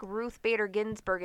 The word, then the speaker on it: Ruth Bader Ginsburg: Like, if they don't know certain, like, pop culture references, they don't know who the fuck Ruth Bader Ginsburg is, if I'm even saying her name right. – Like, no, you got it right.